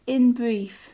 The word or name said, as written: in brief